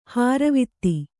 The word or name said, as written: ಹಾರುವಿತ್ತಿ